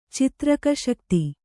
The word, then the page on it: ಚಿತ್ರಕಶಕ್ತಿ: citraka śakti